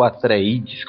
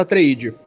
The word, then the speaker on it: Atreides: É, o protagonista do livro é o, o Paul, Paul Atreides, ou Paul Atreides, qual seria a pronúncia?